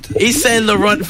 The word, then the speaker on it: Yves Saint Laurent: Yves Saint Laurent fragrance launch.